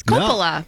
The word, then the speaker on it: Coppola: Never heard it pronounced Coppola before. It's Coppola. – No. – I usually hear Coppola.